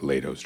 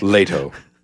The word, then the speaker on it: Leto: I like Lato's Joker more. Lego Joker. That's what you like. Leto.